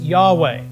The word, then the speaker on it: Yahweh: The Hebrew word is actually probably pronounced like this, Yahweh.